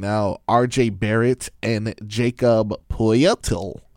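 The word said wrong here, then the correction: Pöltl: How do you say this guy's name? – Portal. – Jakob Portal. Jakob.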